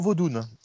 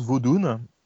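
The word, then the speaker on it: vaudoun: Euh, on, va, parler de la, religion vaudoune. Alors, on l'appelle vaudou pour simplifier, mais normalement, ça se prononce vaudoune.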